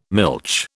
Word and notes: milch: British English

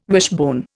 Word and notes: wishbone: Indian

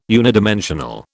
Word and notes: unidimensional: British English

